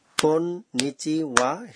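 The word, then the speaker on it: কোন নিচি ওয়া (こんにちは): শুনে মনে হচ্ছে কন্নিচুয়া শব্দের উচ্চারণ বেশ কঠিন হ্যাঁ এই শব্দটিকে সমানতালে তিনটি হাততালির সঙ্গে কোন নিচি ওয়া হিসেবে বলার চেষ্টা করুন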